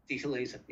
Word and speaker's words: Dieter Laser: Speaking of Dieter Lesser, that's another, or Laser, Dieter Laser. Am I pronouncing that correctly? – Dieter Laser. Yeah.